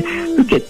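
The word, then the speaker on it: 深井: このおふき焼きとはですね、名古屋城内で焼かれてたですね、いわゆる殿様のお庭焼きってものでですね、で、ふけってのは、深いって字に、井戸の井って書いて、これふけって読むんですけど、はい、これはですね、もともとの意味は沼地とか湿地帯のことを指す言葉なんですね。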